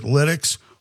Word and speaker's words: Lytx: It's LYTX, okay? And everybody pronounces it Lidex. Why don't we call it Lytex? – Right.